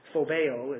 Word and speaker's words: phobeo: The Greek word is phobia. Sounds familiar, doesn't it? Phobeo is the way to pronounce that, but it means total terror.